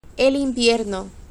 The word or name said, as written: el invierno